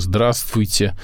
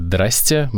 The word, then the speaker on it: здравствуйте: Вот, смотрите, это пишется слово «здравствуйте». Можно сказать «здрасте», можно, «здрасте», можно сказать «драсть», можно сказать «ась».